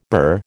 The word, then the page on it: burr: American English